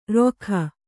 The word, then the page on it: ರೊಖ: Kannada